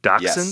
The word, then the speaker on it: dachshund: I also have to acknowledge I got roasted on Twitter because of my pronunciation, and I'm going to say it correctly now, of dachshund.